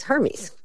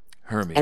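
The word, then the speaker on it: Hermès: And then when we first found out the store, I mispronounced it as Hermes. – Hermes.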